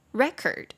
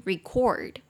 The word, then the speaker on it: record: 比如说录音那个字，录音如果是名词的话，它当记录什么文件啊，或者是记录下来的东西，它重音就会在前面，念作 record。 record 什么什么记录，但是如果当动词录音就会变 record record 终于跑到后面，所以其实，在英文里面很多字都是长这样。